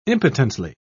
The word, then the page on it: impotently: British English